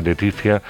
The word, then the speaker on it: Leticia: Está dirigida por la Laetitia se escribe, pero se lee Leticia eh, Colombani.